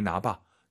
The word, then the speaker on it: 拿: 니 라이 나봐. 니 라이 나봐 하면 니가 와서 가져가 되는 거죠, 선생님? – 是的. – 이렇게 뜻이 여러 가지로 쓸 수 있습니다. 재밌지 않습니까? 너무 재밌어요. 한국어랑 발음도 똑같고 말이죠.